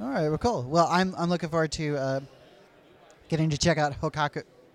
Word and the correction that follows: Kohaku: You're pointing at it, which made me mispronounce it. Looking forward to getting to try out Kohaku. – There you go.